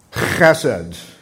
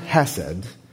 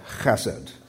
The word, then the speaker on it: chesed: Chesed. Okay. Did anybody not participate in that? Okay, because it's not Hesed. It's a different, actually, Hebrew letter, Chesed.